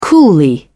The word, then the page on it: coolly: British English